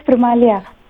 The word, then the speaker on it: Primalia: My name is Primalia. – Primalia. Can you spell that? It is P-R? – P-R-I-M-A-L-I-A. – Primalia. P-R-I-M-A-L-I-A.